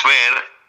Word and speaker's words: Fher: Fer, mi apellido se escribe Fher.